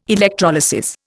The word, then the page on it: electrolysis: Indian